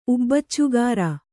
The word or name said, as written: ಉಬ್ಬಚ್ಚುಗಾರ